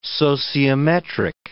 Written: sociometric

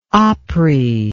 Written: opry

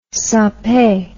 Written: Sempe

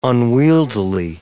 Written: unwieldily

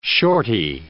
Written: shortie

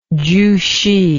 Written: chuhsi

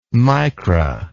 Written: micra

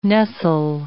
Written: nestle

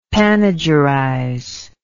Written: panegyrize

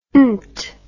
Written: nt

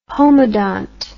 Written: homodont

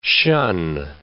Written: shun

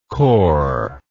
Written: kor

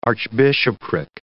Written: archbishopric

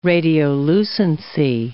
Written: radiolucency

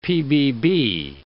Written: PBB

